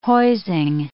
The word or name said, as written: hoising